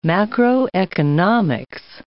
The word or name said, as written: macroeconomics